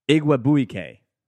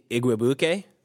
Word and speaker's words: Igwebuike: I want to hear you pronounce this name. It's Godwin Igwebuike. That's my pronunciation. What do you got? – Yeah, I would agree. I think Igwebuike. – Yeah, but Igwebuike. You're just going to ignore the I? I get it. – Yeah. – Ignore the I? – I mean, it's a silent I.